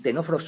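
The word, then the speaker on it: ctenóforos: que tienen C que no se pronuncia, ¿de acuerdo? Que empiezan por una C que no se pronuncia. Como veis, fijaos, los tenóforos solamente tienen aspartato y GABA como neurotransmisores, solamente dos, y los nidarios ya tienen más maquinaria con bastantes más neurotransmisores.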